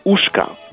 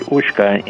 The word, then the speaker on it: uszka: At Polish Christmas meals, people often eat something called uszka.